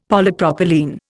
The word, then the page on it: Polypropylene: Indian